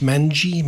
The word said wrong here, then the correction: mange: Mange. Wombat mange.